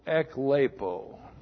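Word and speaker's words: ekleipō: E-K-L-E-I-P, long O, eklepo.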